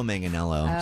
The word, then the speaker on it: Manganiello: Oh, I don't know how to say his last name correctly. It's like Mike Mangarello. – You've already missed it. His name is Joe Manganiello.